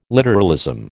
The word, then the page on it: literalism: American English